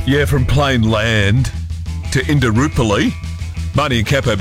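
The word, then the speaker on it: Plainland: I always call it plain land, but it's actually plain land, which doesn't sound right. – There's no S. – Yeah, there isn't an S, but we do that.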